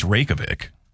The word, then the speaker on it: Reykjavik: You know, actually, it's Reykjavik.